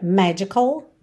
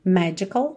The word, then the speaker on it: magical: Aqui a gente acabou tendo essa ênfase na última sílaba. Normalmente a sílaba tônica é a primeira, magical, magical, ok?